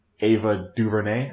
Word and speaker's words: Ava DuVernay: Ava DuVernay? – Yep.